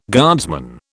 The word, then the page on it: guardsman: British English